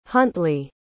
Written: huntley

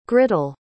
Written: grittle

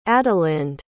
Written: adalinde

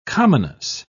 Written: commonness

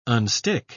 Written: unstick

